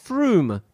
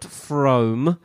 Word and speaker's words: Frome: It is pronounced Froome, not Frome.